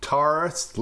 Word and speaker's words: Tarris: Tarris, like the astrological symbol.